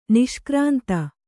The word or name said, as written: ನಿಷ್ಕ್ರಾಂತ